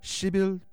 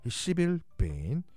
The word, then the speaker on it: 시빌 베인: 그녀의 이름은 시빌 베인. 발음 조심하겠습니다. 시빌 베인. 발음 점 하나 붙이면 큰일 납니다.